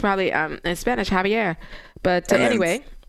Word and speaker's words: Xavier: So regarding the pronunciation of the saint's name, X or Z, you know, Norberto from Indiana emailed, it is pronounced Javier. The X is an H sound.